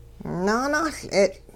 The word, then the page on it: naanuL'e'tL': Hupa